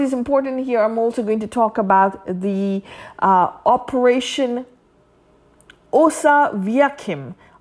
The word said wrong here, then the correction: Osoaviakhim: I can't pronounce it very clearly, my friend. It's Operation Oso Aviakim.